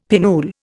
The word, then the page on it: pinnule: Indian